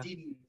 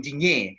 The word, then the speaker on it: Digne: No, now I heard a French person, and he said, no, it's pronounced Luca, and it's not even Lucas, it's Luca, and it's Dean, Luca Dean, but I prefer, because a lot of people are calling him Dignier, you know, and Lucas Dignier, you know, that sounds much better, you know, come on.